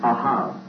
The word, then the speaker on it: ahav: But in the Hebrew, there's the word ahav. Ahav. This is a silent letter here. That's A-H-A-V. Ahav.